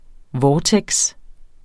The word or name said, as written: vortex